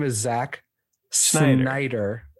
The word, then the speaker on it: Snyder: the Rob Schneider cut. – Oh, did I say it wrong? – You said Schneider. – Instead of? – Snyder.